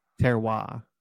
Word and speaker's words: terroir: Like terroir? I don't know. – Terroir.